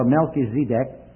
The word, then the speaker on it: Melchizedek: made an high priest forever after the order of Melchizedek, or Melchizedek, as the guide in Israel wanted me to pronounce it.